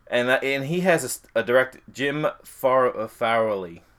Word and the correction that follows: Frawley: I can't pronounce that. – That is going to be a Jim Frawley.